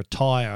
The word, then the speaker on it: TYR: It's from the company's called Safe Tech, and the helmet itself is called the Tyre Plus, T-Y-R. I don't know why they would name a helmet after a tyre, a car tyre, but T-Y-R, how do you pronounce that? Tyre.